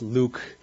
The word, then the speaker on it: LIWC: So from this research, he developed a text analysis software called Linguistic Inquiry and Word Count, L-I-W-C, pronounced Luke.